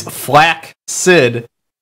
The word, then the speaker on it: flaccid: Andrew says, fun fact, flaccid is one of the most commonly mispronounced words in the English language. The correct pronunciation is flaccid.